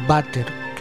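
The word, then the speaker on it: Waterkeyn: Waterkain. En holandés, es como en alemán, como en español, la W se pronuncia como B.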